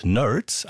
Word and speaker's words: Nurds: Dieses zweite Album erschien 1980 und hieß Nerds, aber wieder anders geschrieben als erwartet, N-U-R-D-S.